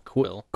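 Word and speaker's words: Kuiil: How do you pronounce his name? Quill? – Quill. – Quill. – Quill. – Quill. Sort of. Yeah. – Like Wheel with a K.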